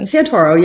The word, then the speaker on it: Santoro: Santoro.